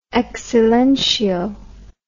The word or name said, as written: ex silentio